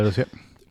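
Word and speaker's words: Col de la Loze: where they finish on Col de la Loz. Is that correct pronunciation? – Yeah, Col de la Loz, 2,300 metres.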